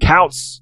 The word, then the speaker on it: Koutz: But if you want to search the author yourself, it's important to note that his last name is spelled K-O-U-T-Z. I said Kautz, K-O-U-T-Z.